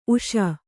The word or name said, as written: ಊಸು